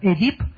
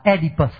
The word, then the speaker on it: Oedipus: À, người mà học văn hóa Pháp thì biết tên chàng này là Oedip. Trong khi cái người cũng cái tên đó về đến tiếng Anh thì người Anh, người Mỹ đọc là chữ là Oedipus.